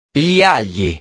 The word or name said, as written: gli agli